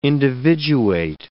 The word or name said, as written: individuate